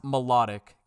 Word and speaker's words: Mlotik: And it's spelled M-L-O-T-I-K. And it's pronounced Melodic.